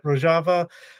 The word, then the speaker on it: Rojava: Dilara, this is a topic, Rojava. I said it wrong again. No, I said it right. Rojava, Kurdistan, the Kurdish Women's Movement.